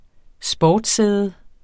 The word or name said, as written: sportssæde